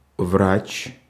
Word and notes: врач: [vratʃ]; /vrat͡ɕ/; /vraʨ/

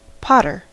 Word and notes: potter: American English

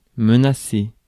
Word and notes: menacer: [mə.na.se]; France

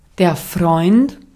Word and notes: ystävä: /ˈys.tæ.væ/

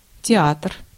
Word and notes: театр: [tʲɪ'atr]; teatr

